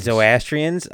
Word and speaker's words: Zoroastrians: The Zoroastrians, which is a... – Zoroastrians? – Oh, thank you.